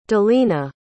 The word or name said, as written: dalena